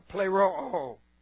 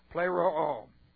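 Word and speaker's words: plero'o (πληρόω): Aorist, passive, infinitive of plero'o. So let's take a look at what we have going on here. Plero'o. Alright, this is P-L long E, R-O long O, plero'o.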